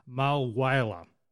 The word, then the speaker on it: Mulwala: His mum and stepdad bought a timeshare at Yarrawonga, and now this is where he's pronounced it, Mulwala. So Yarrawonga Mulwala. That's how you pronounce it.